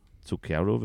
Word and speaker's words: Zucchero: Ich habe nochmal geschaut, also man hat ja dann bei den Quellen auch nochmal sichtbar, wie das ausgesprochen wird, der liebe Künstler, nämlich Zucchero. Zucchero wird dieser Künstler ausgesprochen.